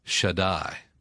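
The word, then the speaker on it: Shaddai (שַׁדַּי): Almighty is Shaddai.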